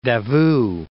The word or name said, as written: Davout